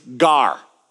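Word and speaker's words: gar (γάρ): And it's a Greek word. It's gamma, alpha, rho. It's gar. Say it. Gar.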